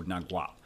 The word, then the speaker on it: Nagual: and the way that, you know, it was recommended we pronounce it is with the G noise, so Nagual.